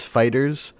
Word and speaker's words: FighterZ: No, do you remember the big joke when Dragon Ball FighterZ came out and they were like, "How do you pronounce it?" And no, they're like, "No, it's Fighters." Like Namco Bandai was actually like, "No, it's Fighters," but the Z's capitalized. And then they're like, "Wait, so why isn't it Balls?" And they're like, "No, it's just for the fighting game. You pronounce it Fighters."